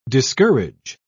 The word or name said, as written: discourage